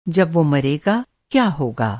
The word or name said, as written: मरना